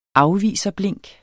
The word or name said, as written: afviserblink